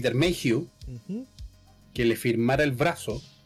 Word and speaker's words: Mayhew: Peter Mayhew.